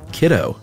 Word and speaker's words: CDO: For example, unless you chat with researchers on a regular basis, how would you know to pronounce the gene BRCA1 as BRCA1 and CDO as kiddo, while PD1 is just PD1? – I don't know how you would know.